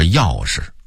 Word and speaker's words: 钥匙: 尾巴北京人说尾巴，凑合北京人说凑合，刮的扁儿北京人说刮的扁儿，跟前北京人说跟前儿，核桃北京人说核桃，夹竹桃北京人说夹竹桃，正经北京人说正经，钥匙，哎，这第二个字儿正确的读音应该念迟啊，同迟到的迟那个音，但是北京人则说成是钥匙。